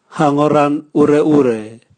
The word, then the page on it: hagoran ureure: Raga